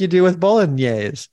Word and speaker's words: bolognese: Of course, in English or American, you normally say it bolognese. Bolognese, but the typical Italian pronunciation is quite different. In fact, in Italian, they say bolognese.